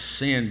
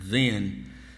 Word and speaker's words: Zin: And sometimes that's pronounced sin, but it's real. It's like a Z sound, Zin, after their journeys.